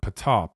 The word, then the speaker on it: Ptah: And that's how you pronounce that word, Ptah. P-T-A-H, Ptah.